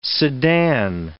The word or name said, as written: Sedan